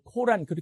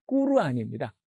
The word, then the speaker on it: 꾸르안: 근데 꾸란, 뭐 코란 그렇게도 얘기를 하는데 사실은 정확한 발음은 꾸르안입니다.